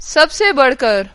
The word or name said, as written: जानिब